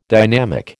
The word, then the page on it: dynamic: American English